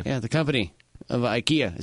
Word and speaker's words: IKEA: We've been pronouncing IKEA wrong. – Really? – Yeah, apparently. – Well, how you say it? – Apparently it's IKEA.